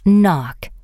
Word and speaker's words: knock: The word knock has a silent K. Today, people do not pronounce the K in this word, but years ago they did. While the spelling of this word did not change, the pronunciation did.